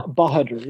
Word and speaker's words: Bahadur: Bahadur, yeah.